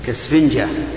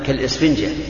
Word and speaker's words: كالإسفنجة: هذه الوصية لا تجعل قلبك كالسفنجة. أنا أحس بها ألف، أحس بها همزة، كالإسفنجة، هكذا ننطق بها. هذه